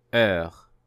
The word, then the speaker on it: heure: Heure. Heure. Écoutez et répétez après moi. Heure. Heure. You will see that this word is spelled with the letter H. However, this letter is not pronounced here.